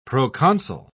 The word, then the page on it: proconsul: British English